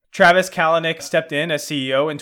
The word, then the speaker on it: Kalanick: I, I don't know if I'm pronouncing the last name right, but Kalanick. Is it Kalanick? Yeah, um, or I Kalanick. Right. Um, it's it's Kalanick.